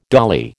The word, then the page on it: dolly: American English